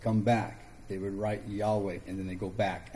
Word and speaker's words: Yahweh: It has no vowels, so you really don't know how you're supposed to pronounce the word. So we use the word Yahweh, or we use the word Jehovah. Most scholars think Yahweh is the closer to the correct pronunciation.